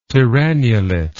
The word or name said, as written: tyrannulet